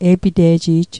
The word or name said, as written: e'pite'ji'j